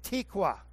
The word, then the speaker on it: tikwa: Hope means tikwa, is the Hebrew pronunciation.